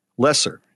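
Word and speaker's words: Loesser: This song was written by a husband and wife, Frank Lawser, and his wife, Lynn Garland. – Lesser. – Yeah. Oh, is that how it's pronounced? – That's how it's pronounced. – Yeah. Losser. Lesser. Okay. Thank you.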